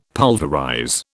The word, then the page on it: pulverize: British English